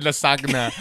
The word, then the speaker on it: lasagna: Like, the G is silent. – In lasagna.